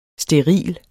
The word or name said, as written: steril